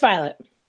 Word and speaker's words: Vialet: It's just Vialet.